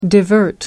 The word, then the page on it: divert: American English